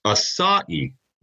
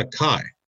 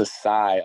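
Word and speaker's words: açaí: It's it's asai, right? Not a kai. – No, it's asai.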